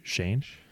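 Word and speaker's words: Schoen: Joe Shane, I believe is that how you pronounce it? S-C-H-O-E-N. I think it's Shane.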